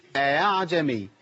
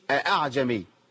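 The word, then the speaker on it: أأعجمي: كلمة أعجمي بيتعامل معاها بتسهيل الهمزة، يقول إيه؟ أعجمي، ما أقولش أعجمي.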